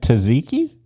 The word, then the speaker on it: tzatziki: How is that pronounced? Is that tzatziki? – Yeah. – Oh, okay. Because I always mispronounce it and people are looking at me like, what is he talking about? – I'm wondering how you mispronounce it. – I did, because I'm looking at the spelling. – Okay. – And I'm trying to sound out every letter.